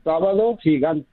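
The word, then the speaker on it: Sábado Gigante: Sabato Gigante.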